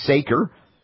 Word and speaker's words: Saker: County prosecutor by the name of Kelly Saker, rhymes with faker, targeting raw food enthusiasts while factory farms are polluting the environment with reckless abandon?